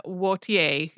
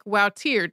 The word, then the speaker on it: Wautier: These paintings turned out to be by 17th century Flemish painter Michaelina Wautier, who you will also hear pronounced more like Wautier, depending on whether the person is using a French pronunciation or a Dutch one.